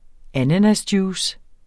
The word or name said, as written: ananasjuice